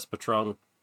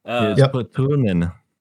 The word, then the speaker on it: Patronen: The other thing, we were talking about the SS Patron. Or how do you pronounce that? Does it sound like the liquor? – Patron? SS Patron. Patron, yeah. – Patronen. That's the correct German pronunciation.